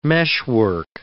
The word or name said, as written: meshwork